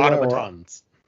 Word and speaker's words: automatons: conquer the both the bugs and the automons. Is how you say automatons. – That? Automatons. There you go on there.